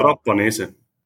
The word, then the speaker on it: Trapanese: Wie wird denn dein Nachname nochmal ausgesprochen? – Genau. Pratanese. – Pratanese, okay. Pratanese mit T, genau.